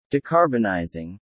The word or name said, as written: Decarbonizing